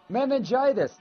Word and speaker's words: meningitis: And while I'm just having at it with you, Meganitis. I wish Lee was here to tell me how do you pronounce that, Lee? Meningitis. Thank you, Lee, baby.